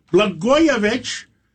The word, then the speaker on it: Blagojevich: Well, Blagojevich rhymes with Katz and Matidis.